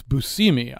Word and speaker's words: Buscemi: Oh, I just got told I mispronounced. It's Buscemi. I never knew that.